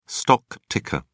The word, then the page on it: stock ticker: British English